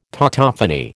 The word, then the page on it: tautophony: American English